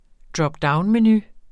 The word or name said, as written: dropdownmenu